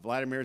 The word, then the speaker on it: Vladimir: Vladimir is how you say it in Russian. Vladimir is how you say it in Ukrainian.